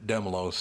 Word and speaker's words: Dehmalo: We wanted to finally get the last word, Demlow said. Now, they have his name spelled the same way, D-E-H-M-A-L-O. So it's Demlow, but her name was Demlow.